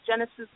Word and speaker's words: Genesis: So my name is Genesis B. I spell that G-E-N-E-S-I-S, like the first book, the letter B, the letter E.